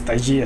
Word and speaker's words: Taiji: Él comentó que el Taiji, eh, porque no es Tai Chi es Taiji, así se, pronuncia, eh, proviene, es una rama del Kung Fu.